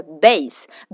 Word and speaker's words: base: 两个音节，第一个音节是 base, b a s e, base, base 中间有个不发音的 e，OK，非常简单，对不对？base, b a s e，最后就是 ball 啊，跟 basketball 一样，b a l l 是一个球，OK, baseball 怎么拼的怎么写，对吧？